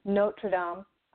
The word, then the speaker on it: Notre Dame: but was the Notre Dame, or Notre Dame, sorry, I said it wrong, Notre Dame Cathedral and the burning, and then now more recently, to add to that story, they're talking about how it may not get rebuilt.